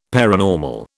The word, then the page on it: paranormal: British English